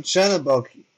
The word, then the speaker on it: Chernobog: Uh, I mean, Chernobog, you're close. Um, it's just B O G.